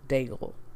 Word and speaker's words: Daigle: Let me actually pronounce my last name. Daigle. Like bagel, but with a D.